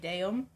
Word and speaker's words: Daum: It looks like damn, but it's not. It's like daum. D-A-U-M.